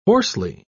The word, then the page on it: hoarsely: British English